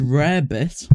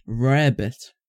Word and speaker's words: rarebit: Rarebit. – Is that like an American rabbit, but in Wales? – It does sound like that. Rarebit. No, it's spelled like rare. It's spelled like rare and bit.